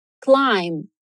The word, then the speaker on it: climb: Climb. Pois é, essa palavra é escrita com B, mas a gente não vai pronunciar esse B, ou ele é quase imperceptível, ok? – Então, de novo crime E crime, crime.